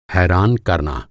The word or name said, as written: हैरान